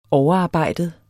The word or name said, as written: overarbejdet